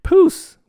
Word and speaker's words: Pousse: Pousse, which rhymes with goose or juice, by the way, is essentially a shade of purple tinted with brown.